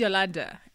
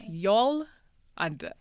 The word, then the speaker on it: Yallunder: My name is Yallunder. It's Yallunder. So it's Yallunder.